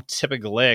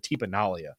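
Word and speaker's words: Tipa Galeai: I always call him Tipa Galea because that's how his name is spelled. – Right. – And he doesn't play enough to hear his name said on TV very often, but I think he pronounces it Tipa Nalia.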